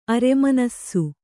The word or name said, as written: ಅರೆಮಾನಿಸ